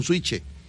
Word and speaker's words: switch: Pues que está faltando el switch, ese que para activar el compresor, un switch, así se pronuncia, vaya, un switch.